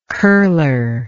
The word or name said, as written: curler